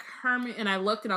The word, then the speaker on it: Hermione: First off, we now know how to pronounce Hermione. Mm-hmm. I was like, again, when Ami came in and was like, Hermione, I was like, she's so lucky.